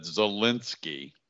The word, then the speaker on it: Zelensky: I don't have the heart to tell him that Zelensky, see now, Joe, that's how you say it.